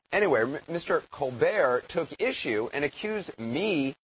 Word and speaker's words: Colbert: What? Colbert? Really? Are you sure? The T is silent. Stephen Colbert, apparently.